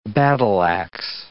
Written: battle-ax

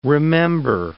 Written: remember

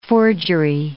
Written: forgery

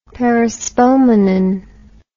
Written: perispomenon

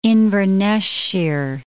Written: Inverness-shire

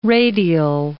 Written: radial

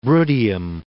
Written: Bruttium

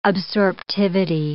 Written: absorptivity

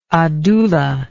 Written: Adoula

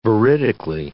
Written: veridically

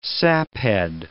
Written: saphead

